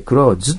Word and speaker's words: clothes: C は clothes、発音はね、 TH の発音しなくて clothes ということも正しいんですよね。